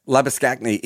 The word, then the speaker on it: Labuschagne: Like, you know, that change the way, like there's literally two ways of pronouncing Manus Labuschagne, right? Like Labuschagne is a correct version of that, but he wants the more anglicized version of it.